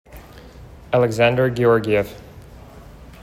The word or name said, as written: Alexsandar Georgiev